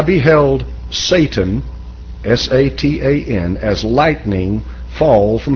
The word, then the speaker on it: Satan: Because in Hebrew, it is spelt exactly the way you see it here and pronounced almost the way that an Englishman would pronounce it today. It's Satan, and that is a adversary.